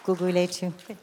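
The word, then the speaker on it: Gugulethu: Good pronunciation, Guguletu.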